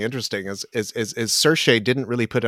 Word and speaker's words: Saoirse: And I did find out from my Irish friends that the pronunciation of her name is Saoirse. Don't question it. Just don't even look at the letters when trying to pronounce.